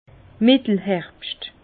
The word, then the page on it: Mittelherbscht: Bas-Rhin; Herrlisheim